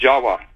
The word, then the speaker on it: Java: The word in Java, the island Java, you know, is pronounced Jawa, actually. Indonesians will pronounce it Jawa, not Java like we do.